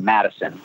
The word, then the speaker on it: Mattison: Just as if it was a D, like the city of Wisconsin, Mattison.